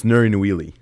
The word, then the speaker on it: Nouri Nouili: Am I saying it wrong? – Yeah. – You're smiling. – A little bit. It's Nouri Nouili.